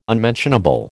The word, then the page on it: unmentionable: American English